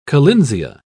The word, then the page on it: collinsia: British English